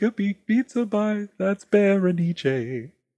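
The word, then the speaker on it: Bérénice: Best supporting actress goes to Octavia Spencer for The Help, beating out Bernice Bayo for The Artist. His name, I'm pretty sure I butchered. – Well, I was going to say Berenice also, so good job. – Thank you. – Berenice.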